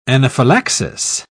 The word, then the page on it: anaphylaxis: British English